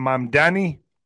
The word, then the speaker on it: Mamdani: How do you say his name? And Mamdani? – Mamdani.